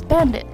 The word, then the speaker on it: BVNDIT: So the girl group's name will be spelled B-V-N-D-I-T, but according to the Korean spelling, the name is pronounced as Bandit.